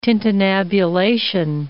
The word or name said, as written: tintinnabulation